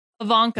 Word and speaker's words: Evancho: But now I know Ivanko. Who she was. And she is, how do I say it? Ivanko? Ivanko. Yeah. Hey, Jackie Ivanko.